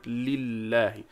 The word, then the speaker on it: لله: We should pronounce the alif here in the word لله.